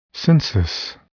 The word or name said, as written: census